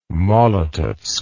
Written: Molotovsk

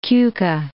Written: Keuka Lake

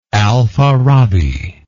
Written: al farabi

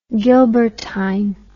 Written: gilbertine

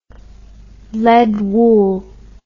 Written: lead wool

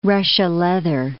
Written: Russia leather

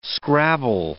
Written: scrabble

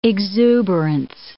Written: exuberance

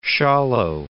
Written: Schawlow

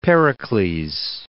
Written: Pericles

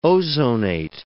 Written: ozonate

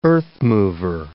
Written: earthmover

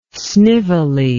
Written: snively

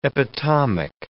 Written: epitomic

